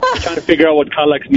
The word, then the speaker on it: Kallax: For example, how do you say K-A-L-L-A-X? Kallax? – Kallax.